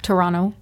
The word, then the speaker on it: Toronto: Did I say that correctly? Toronto. – That's correct. You do not pronounce the second T. That's so prissy.